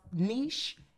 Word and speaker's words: niche: Yes, we had this argument, but do you know that it technically niche? Has two pronounced niche and niche? It has two correct pronunciations. Okay. You get that argument all the time like, it's niche, it's niche. – Okay. – It's actually both.